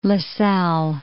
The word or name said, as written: LaSalle